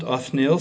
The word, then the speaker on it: Othniel: But I'm quite sure that that's how it is pronounced, Othniel.